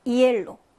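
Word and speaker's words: hielo: 얘는 무음이네요. 아체. 그러면 어떻게 읽으시면 된다? 이엘로 하고 읽으시면 됩니다.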